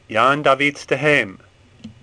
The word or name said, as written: Heem